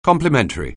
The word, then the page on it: complimentary: anglais